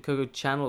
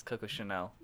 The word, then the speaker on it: Coco Chanel: Is it Coco Channel or Coco Chanel? By Loves Chanel. Cat. – Yeah, it's spelled channel, deliberately, isn't it? Yeah. Like Channel. – But yeah, it's Coco Chanel.